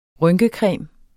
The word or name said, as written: rynkecreme